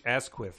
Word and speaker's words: Asquith: Asquith. – H.H. Asquith. Herbert Henry Asquith. – Emphasis on the ass.